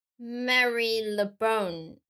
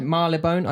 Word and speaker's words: Marylebone: Mar but it's spelled like Mary LeBone.